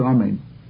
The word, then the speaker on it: Amen: Whoever answers Amen shouldn't answer a grabbed Amen. That means he doesn't pronounce it properly. Rashi says that he doesn't pronounce the Aleph with the proper vowels, the kamats under the Aleph. V'lo Amen ketufa, nor an Amen that is cut off. Rashi explains that that's with the Nun missing. He cuts off the Nun at the end. V'lo Amen ketzara, not an Amen that's pronounced too shortly. V'lo Arucha, not one that's stretched out too long.